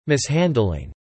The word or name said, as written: mishandling